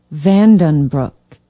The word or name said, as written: vandenbroek